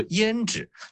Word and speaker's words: 胭脂: 对，但是呢很巧的是在上海话中这两个词烟纸和胭脂他们的发音都是一样的，所以都叫一字地。